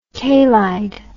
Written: kalied

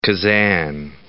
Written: Kazan

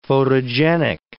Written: photogenic

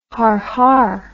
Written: har-har

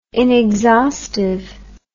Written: inexhaustive

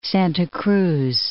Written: Santa Cruz

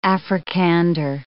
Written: Africander

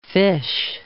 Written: fish

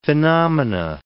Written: phenomena

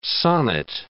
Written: sonnet

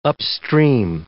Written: upstream

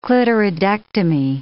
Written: clitoridectomy

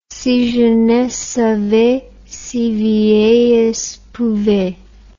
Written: si jeunesse savait si vieillesse pouvait

